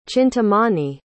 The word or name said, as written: chintamani